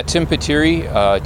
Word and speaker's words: Tim Pitirri: Yeah, Tim Petturi, T-I-M-P-I-T-I-R-R-I.